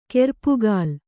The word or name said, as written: ಕೆರ್ಪುಗಾಲ್